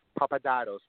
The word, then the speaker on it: Papadatos: Papadados, my whole last name.